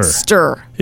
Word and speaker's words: hamster: Hamster. – It's hamster. There's no P in there.